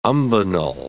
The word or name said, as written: umbonal